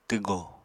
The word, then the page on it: twgò: apatani